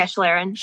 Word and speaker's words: Schlieren: As far as is it Schlieren test? Is that how you pronounce that? – Yeah, Schlieren. Yeah.